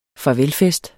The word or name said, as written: farvelfest